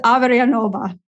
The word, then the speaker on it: Averyanova: It's my husband's last name, Elizabeth Averyanova.